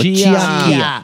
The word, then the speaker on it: Chía: ¿Cómo se dice su apellido? – Chía. – Aquí le decimos Chía.